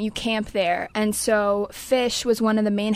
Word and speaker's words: Phish: It's spelled P-H-I-S-H for anybody that doesn't know.